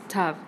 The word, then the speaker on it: Tav: The most dramatic split between Ashkenazim and Sephardi Hebrew is the pronunciation of the final letter of the Hebrew alphabet, which Ashkenazi communities pronounce Sov and Sephardic congregations pronounce Tav before the Holocaust.